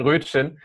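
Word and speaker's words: Röttgen: die man dann schnell aus Rüttgen ähm, oder Rötchen, ich gibt es Namensdebatten, wie es ausgesprochen wird. Nein, es ist nur ähm, eine. Rötchen wie Brötchen. – Okay, dann Rötchen.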